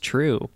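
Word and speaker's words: TRVE: It's just True, but it's spelled with a V because it's metal.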